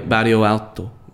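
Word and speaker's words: Bairro Alto: Barrio Alto.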